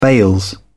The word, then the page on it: bails: British English